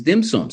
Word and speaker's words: dim sum: You know, and um, by the way, tenshin in Cantonese pronunciation is dim sum.